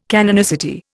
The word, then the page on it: canonicity: Indian